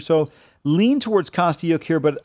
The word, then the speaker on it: Kostyuk: Kostyuk? – Marta Kostyuk.